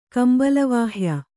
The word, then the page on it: ಕಂಬಲವಾಹ್ಯ: kannada